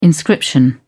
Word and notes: inscription: British English

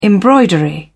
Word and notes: embroidery: British English

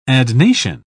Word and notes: adnation: British English

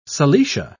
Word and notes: silesia: British English

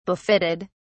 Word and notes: befitted: British English

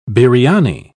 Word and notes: biriani: British English

